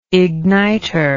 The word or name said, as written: igniter